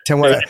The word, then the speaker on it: Temuera: Temuera. – Temuera.